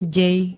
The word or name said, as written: dej